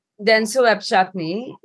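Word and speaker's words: Dentsu Webchutney: So you're working in an Indian agency, it's called, was I right, Denso Webchutney? – Denso Webchutney, now it's Denso Creative, part of the Denso network as a whole.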